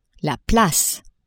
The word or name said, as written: place